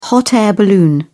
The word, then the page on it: hot-air balloon: British English